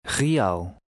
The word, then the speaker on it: real: The two vowels will be pronounced separately. Try to read number 7. – Real.